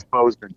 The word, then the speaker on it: Bozeman: It's Bozeman. – All right. So if people hear about Bozeman, Montana, now there's like really no direct flights from New York to Bozeman. Bozeman. Bozeman.